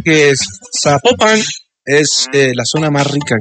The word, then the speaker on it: Zapopan: Oiga, nos escriben los oyentes y que no se pronuncia Zapopan, sino que se pronuncia Zapopan. – Ah, Zapopan.